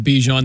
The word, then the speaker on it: Bijan: I was saying Bijan, you're saying Bijan. We're going to go with Bijan. That's the correct way to say Mr. Robinson.